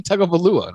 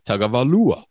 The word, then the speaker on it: Tagovailoa: And he's like, you don't have to say it like that. Just say Tagovailoa. And I'm like, no, you got to say it. Tagovailoa. – Yeah, you got to say it the way they say it, man.